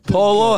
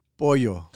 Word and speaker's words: pollo: Polo, how do you say it? – Pollo.